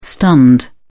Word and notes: stunned: British English

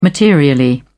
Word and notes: materially: British English

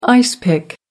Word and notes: ice pick: American English

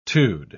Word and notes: -tude: British English

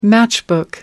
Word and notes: matchbook: British English

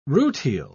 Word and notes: rutile: British English

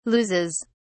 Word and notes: loses: British English

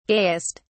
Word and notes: gayest: British English

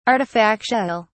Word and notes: artifactual: British English

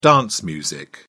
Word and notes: dance music: British English

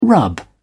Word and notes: rub off: British English

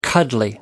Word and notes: cuddly: American English

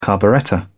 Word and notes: carburettor: British English

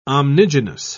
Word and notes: omnigenous: British English

